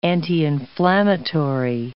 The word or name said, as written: anti-inflammatory